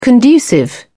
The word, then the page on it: conducive: British English